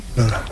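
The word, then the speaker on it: Norah: Acaba de No como la de Ibsen. ¿No cambia la pronunciación o qué? – No cambia. – Nora, ¿no? – Nora. – La de Ibsen suena igual, ¿no? – La madre de Borges, ¿no?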